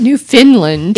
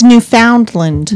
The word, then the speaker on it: Newfoundland: But there's no I. – Newfoundland. – There's no I. – Not Newfoundland. – It's Newfoundland.